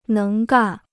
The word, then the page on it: 能干: néng gàn